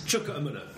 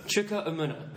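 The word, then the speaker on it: Chuka Umunna: Can you clear up how to pronounce his name, please? – Chukwuemunna. – Chukwuemunna. Good.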